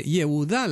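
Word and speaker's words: Yehudá (יהודה): Bueno, si leyéramos en hebreo, entenderíamos que justamente Yehudá, la palabra Yehudá, que dicen Judá en castellano, omitiendo una sílaba, Yehudá tiene al principio el nombre mismo del Señor.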